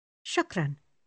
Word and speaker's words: shukran: Shukran. – She repeats the word, attempting to emulate his rolling R. – Shukran. – If you wish to say thank you very much, you say shukran bezef.